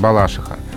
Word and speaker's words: Балашиха: И это вариант «балашиха». А, и интересно, что словари русского языка, словари, адресованные работникам СМИ, а, поддерживают местный вариант. То есть ударение «балашиха» зафиксировано в словарях. И хотя оно ну, непривычно многим носителям языка, но особенно перед микрофоном надо говорить именно так.